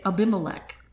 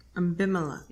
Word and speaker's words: Abimelech: That's how I learned how to say Abimelech. – Abimelech. – Yeah.